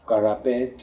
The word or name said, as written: Karapet